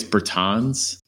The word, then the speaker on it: Bertans: Um, did I say his name wrong? It's Bertans. It's Bertans, but I mean, I you know, I gotta like hold my pinky out when I say that.